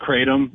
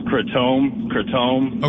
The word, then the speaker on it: kratom: Anyways, I would encourage anybody who's interested in this subject, in the drug, like learning more about the drug itself, which we call it kratom. That's how most people pronounce it. I think like in its indigenous region or whatever, it's called kratome. – Okay. – Something closer to that.